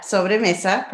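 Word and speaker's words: Sobremesa: Thank you so much for coming on Moms Don't Have Time to Read Books to discuss Sombre Mesa. Did I say that right? – Yeah, Sobre Mesa, perfect.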